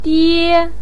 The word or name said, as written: die1